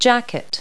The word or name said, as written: jacket